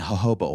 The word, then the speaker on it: jojoba: I mix up some brown sugar, some honey, coffee grounds, and jojoba oil. Jojoba? The J is silent.